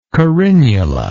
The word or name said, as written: carinula